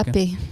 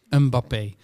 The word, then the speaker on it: Mbappé: Want ja, een van de sterren van dit EK eh, e is natuurlijk, eh, hoe zeg je dat? Mbappé? Of mag je die M niet uitspreken? – Mbappé. Uh, Mbappé. – Mbappé.